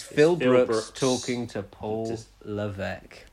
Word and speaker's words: Levesque: He says, "Peeve, Paul Levesque, pronounced Levesque as it's French Canadian, was referred to by his actual name by CM Punk when they were having a Barney one time.